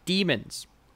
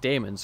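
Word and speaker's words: Dæmons: And he also, this is also where he says that he is the last of the, and he says demons. which is wrong, because we know it's pronounced daemons, because they're from the planet Deimos, as the doctor said, but now he's saying demons, and it's frustrating as fuck.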